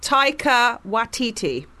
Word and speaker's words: Taika Waititi: It's pronounced Taika Waititi.